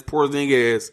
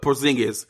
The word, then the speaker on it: Porzingis: She's like, he's not Mexican. He's Porzingis, and he's not Mexican. I'm like, okay, I'm sorry. I said it wrong, but it's Porzingis. I don't think I'm saying it wrong again, but Porzingis. Yeah, okay, now I said it right.